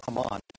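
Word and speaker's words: Amman: We typically pronounce it Ammon when we read it in the Old Testament, but it's Ammon.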